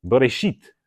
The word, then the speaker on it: Bereshit: And it's, as you can guess, when you put it in English, the last four letters are S-H-I-T. Of course, we pronounce it sheet, Bereshit. You have to be careful how you say Bereshit, right? It's not bear. That's what you find in the woods.